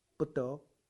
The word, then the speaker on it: 佛陀: 那你用台湾闽南话念怎么念？不得，哎，不 B 对不对？得 D right， BD 是不得，闽南话念对了，普通话念错了，佛陀是 FT，说所以我说唐朝的时候他们讲闽南话。